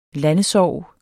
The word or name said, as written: landesorg